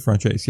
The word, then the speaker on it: francese: Chicken francese.